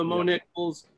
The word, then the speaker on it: Mo Niklz: So I just love the Mo Niklz, three syllables, like literally just six, or I guess seven letters, right? My misspelling, forgetting how to spell my name. – Right. – Yeah, just three syllables, pretty easy.